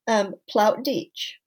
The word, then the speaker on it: Plautdeutsch: You were very close. Plautdeutsch.